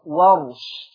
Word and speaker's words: ورش: بفتح الواو وسكون الراء، ورش.